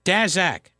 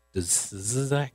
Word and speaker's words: Daszak: Peter Daszak or Dazak, Tizak, Dazak. That's how he pronounces that, I think.